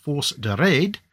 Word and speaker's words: Force de Raid: The two were immediately placed into what I'm reliably informed is actually pronounced the Force de Raid,